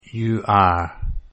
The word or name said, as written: you are